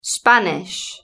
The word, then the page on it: spanish: British English